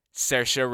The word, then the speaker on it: Saoirse: He's made three feature films, the most recent of which being an adaptation of Chekhov's The Seagull, starring, repeat after me, Saoirse Ronan. I will never stop flaunting my accurate pronunciation of that name.